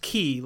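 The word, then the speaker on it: key (χ): It's the Greek character key. – Yeah, it's pronounced key, like the Greek character C-H-I.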